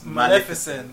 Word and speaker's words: Maleficent: Every time I say, I feel like I'm pronouncing wrong. – Well, in the trailer, she says Maleficent. – Maleficent. – So I guess that's how it's pronounced.